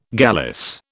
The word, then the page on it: gallice: British English